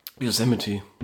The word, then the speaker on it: Yosemite: Yosemite.